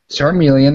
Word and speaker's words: Charmeleon: A Charmeleon, dude. – Charmeleon, that's right, right? – Yeah, that's right.